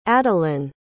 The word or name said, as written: adilynn